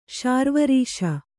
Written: ಶಾರ್ವರೀಶ

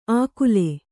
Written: ಆಕುಲೆ

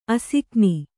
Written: ಅಸಿಕ್ನಿ

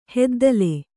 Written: ಹೆದ್ದಲೆ

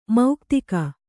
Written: ಮುಕ್ತಕ

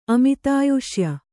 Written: ಅಮಿತಾಯುಷ್ಯ

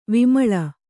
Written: ವಿಮಲೆ

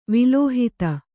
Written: ವಿಲೋಹಿತ

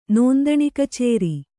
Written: ನೋಂದಣಿಕಚೇರಿ

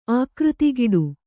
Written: ಆಕೃತಿಗಿಡು

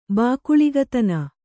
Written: ಬಾಕುಳಿಗತನ

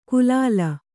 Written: ಕುಲಾಲ

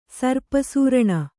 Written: ಸರ್ಪಸೂರಣ

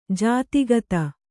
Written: ಜತೆಗಾತಿ

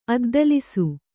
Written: ಅದ್ದಲಿಸು

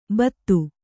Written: ಭಾತಿ

